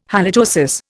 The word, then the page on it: halitosis: Indian